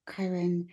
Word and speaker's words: Kyron: So I know your husband, Kyron, Did I say that right? – You did.